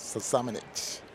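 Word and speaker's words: Samanić: Samanich.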